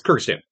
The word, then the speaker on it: Kyrgyzstan: And they're from Kyrgyzstan, you said? – Yes, Kyrgyzstan. – Kyrgyzstan. I didn't think I said that right.